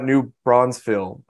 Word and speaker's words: New Braunfels: Then you got New Braunfels, I believe I'm saying that right, as being like the second New Braunfels.